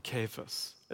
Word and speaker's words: Cephas: And then he changes his name mid-paragraph and says Cephas or Cephas, as it would be pronounced in Aramaic.